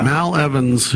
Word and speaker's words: Mal Evans: Is that how you say it? Mal? Mal Evans? – M-A-L, yeah. – Mal Evans, who was the, quote, fifth Beatle.